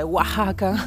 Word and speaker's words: Oaxaca: Eh, eccoci qua, quindi sono arrivata ieri a Oaxaca, che però si scrive Oaxaca, che però si legge Oaxaca.